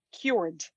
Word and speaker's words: Kyoord: And so what is and It's actually, and it's actually, well, it's spelled, it's pronounced cured.